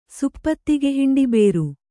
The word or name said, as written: ಸುಪ್ಪತ್ತಿಗೆಹಿಂಡಿಬೇರು